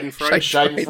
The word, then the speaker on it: Schadenfreude: On the other hand, like, I'm glad this is happening to him for the, what is the German word? Freudenschatter? Or how do you say it? – Schadenfreude. – Schadenfreude, yeah.